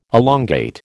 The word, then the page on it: elongate: American English